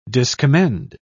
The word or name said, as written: discommend